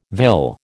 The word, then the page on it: vill: American English